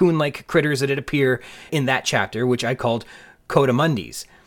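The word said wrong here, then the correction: coatimundis: But I was not pronouncing them correctly. They don't sound like something you wear in the winter, like a Cotamundi, but instead like Croatia. They are Coatamundis.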